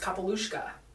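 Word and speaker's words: capaill uisce: she was the one who taught me that that book, The Horses Are Pronounced Kapalushka. – Oh. – It's like a, I was like, it's like a Russian name. It's like these horses that come out of the water. It was like a fantasy book. And I was like, I've been pronouncing that wrong the whole time.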